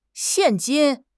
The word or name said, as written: xian4 jin1